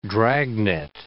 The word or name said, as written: dragonet